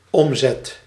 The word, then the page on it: omzet: /ɔm.zɛt/; Dutch